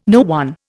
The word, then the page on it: no one: Indian